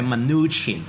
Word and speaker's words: Mnuchin: 其實個讀音是 Mnuchin，是三個字音，三個字音節嚟嘅，